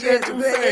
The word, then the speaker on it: étouffée: Shout out to you, said it right, crawfish étouffée.